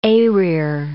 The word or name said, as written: eyrir